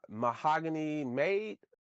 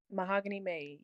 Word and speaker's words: Mahogany Made: Mahogany Made, correct? – Mahogany Made, yes.